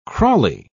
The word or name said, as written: crawly